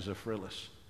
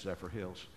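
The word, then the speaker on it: Zephyrhills: And I honestly and truly thought you pronounced that Zephyrillis, and I honestly did until one day I looked at it and said, "Oh, gee, that's Zephyr Hills," and.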